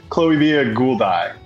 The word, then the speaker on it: Chloebia gouldiae: The Gouldian Finch, Chloebia gouldiae. Is that right? Did I say it right? – Chloebia gouldii.